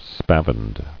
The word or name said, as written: Spavined